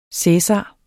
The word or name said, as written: cæsar